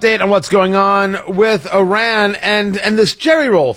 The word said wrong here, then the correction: Jelly Roll: thing. I'm sorry, Jelly Roll. I got his name wrong.